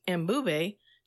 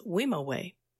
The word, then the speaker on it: Mbube: Once it reached the U.S., the music industry never gave him credit and mispronounced Mbube to what we now know today as Wimowe.